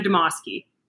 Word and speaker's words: Demaske: It's Maddie Demoski.